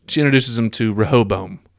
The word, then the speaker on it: Rehoboam: Rehoboam. – Rehoboam, yes, it.